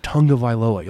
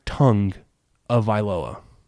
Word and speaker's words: Tagovailoa: It's not Tonga Vailoa. Like it's like Tongue of Vailoa.